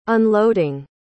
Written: unloading

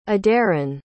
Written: aderyn

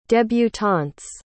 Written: debutante's

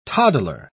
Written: toddler

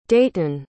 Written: deighton

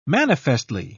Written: manifestly

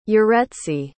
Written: yaretzi

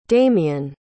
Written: damian

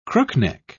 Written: crookneck

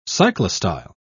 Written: cyclostyle

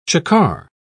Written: chukar

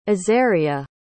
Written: azaria

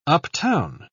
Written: uptown

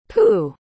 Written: poo

